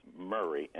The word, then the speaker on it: Murray: It's Murray, M-U-R-R-A-Y.